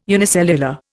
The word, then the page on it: unicellular: Indian